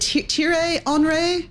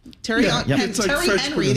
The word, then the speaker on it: Thierry Henry: And they also hired, is it Thierry Henry? – Yeah. – Is this how you say it? Terry Henry, the American way, former star player as its director of football.